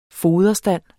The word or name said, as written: foderstand